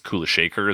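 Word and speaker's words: Kulasekhara: It's spelled K-U-L-A-S-E-K-H-A-R-A. I imagine that's Kulashekar is how you pronounce it, or something close to that.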